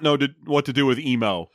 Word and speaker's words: Emmo: It's spelled E-M-M-O, but it's pronounced Emo.